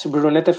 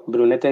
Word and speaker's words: Brunete: How do you say that? – That's Brunete for Geo. Brunete is the place where it's going to be held.